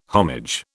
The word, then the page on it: homage: British English